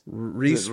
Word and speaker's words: Rhys: Yeah, Jonathan Rhys Meyers. Did I say that right? – Yeah. Or Reese? – Reese Rhys. Yeah.